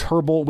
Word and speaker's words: Herbal: It's herbal. You pronounce the H and you put the E in there. Herbal, herbal essences.